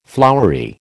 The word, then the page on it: flowery: American English